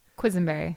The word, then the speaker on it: Quisenberry: Quisenberry. – Quisenberry. Yeah. Okay. Just like it's written. – Yes. Yeah. Pretty phonetic.